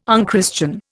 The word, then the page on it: unchristian: Indian